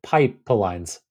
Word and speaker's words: Pypelines: If you pronounce it Pipelines, Pipelines.